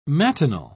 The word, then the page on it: matinal: British English